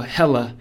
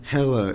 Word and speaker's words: Hela: The most exciting thing I am excited for is to see what's her name? Hera? – Oh, Hela. – Hela. Yeah.